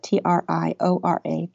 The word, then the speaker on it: Triora: You would spell it T R I O R A, Triora, we would say.